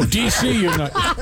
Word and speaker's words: DC United: Last summer, a baby ran onto a soccer field during a game between Dick United. How do I say that? – DC. – Oh, DC United.